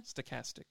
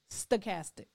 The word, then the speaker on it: stochastic: Pronounce it again. – Stochastic. – Stochastic. Okay. Yeah. – Stochastic. – Not sarcastic. No.